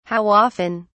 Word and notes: how often: British English

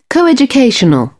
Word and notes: coeducational: British English

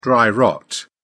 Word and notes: dry rot: British English

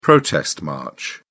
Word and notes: protest march: British English

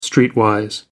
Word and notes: streetwise: American English

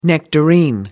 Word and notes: nectarine: American English

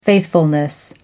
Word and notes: faithfulness: American English

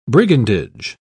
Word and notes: brigandage: British English

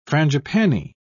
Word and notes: frangipani: British English

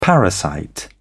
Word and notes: parasite: British English

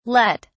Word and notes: lett: British English

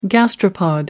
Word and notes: gastropod: American English

